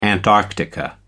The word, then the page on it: antarctica: American English